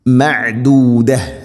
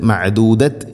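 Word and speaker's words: معدودة: മഅദൂദ മഅദൂദ ഇങ്ങനെയാണ് ഓതേണ്ടത്, മഅദൂദത് എന്നല്ല മഅദൂദ.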